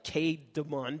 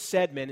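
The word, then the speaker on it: Cædmon: Looks like K. Demon, but it's Sedmon is how you pronounce it.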